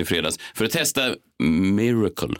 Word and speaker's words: Myrkl: Så är det rätt nu? Miracle. – Ja, miracle. miracle. Inte Miracle. Jo, Miracle. Ja, precis. Men det stavas ju M-Y-R-K-L. Alltså, du uttalar det som det stavas, tror jag. Ja. M-Y-R-K-L.